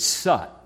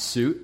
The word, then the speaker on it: soot: And when it did, all of that black soot, and by the way, that's the correct West Virginia pronunciation. You hear me? It's soot, not suit. It's soot.